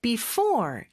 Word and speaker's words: before: before 이음절의 강세가 있고 sunset S 발음 두번 나오는데 이렇게 앞에서 나는 소리라고 보시면 됩니다.